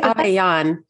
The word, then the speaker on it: Avellan: It's actually Avellan, like you're yawning.